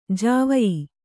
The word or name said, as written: ಜಾವಯಿ